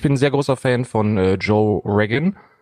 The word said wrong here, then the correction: Rogan: Äh, ich weiß nicht, ob Rogan. ich das richtig ausspreche. Rogan. Rogan.